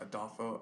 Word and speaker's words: Adolfo: But it's just Adolfo Avalos. – Mm-hmm. – Adolfo spelled A-D-O-L-F-O. And then Avalos is spelled A-V-A-L-O-S.